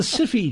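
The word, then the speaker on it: Syfy: It's the, well, let's pronounce it the way it's spelt. And it's the Syphy channel, okay? – Okay.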